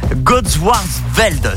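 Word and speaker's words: Godewaersvelde: On est à Godswarsveld, c'est ça? – C'est ça, tout à fait. – Wow, bien prononcé.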